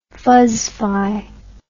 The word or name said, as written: fuzzify